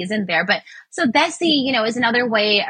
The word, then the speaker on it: Desi: And then there was a word that I learned through reading the book called Desi. – Desi. Yeah, no, you got it. Yeah, Desi. Yeah. – I've been trying to listen to, you know, the pronunciation on Google. It's spelled D-E-S-I.